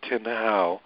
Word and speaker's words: Tin Hau: Guardians of Goddess Tin Hau.